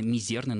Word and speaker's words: мизерный: Именно отсюда прилагательное «мизерный» нужно произносить с «мизерным», с ударением на втором слоге.